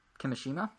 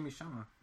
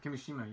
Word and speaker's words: Kimishima: Did I say that right, you think? – Yeah, I think so. And he's been in that role since 2000. – No, Kimishima. – Kimishima? But, okay. – Kimishima, yeah, you're right.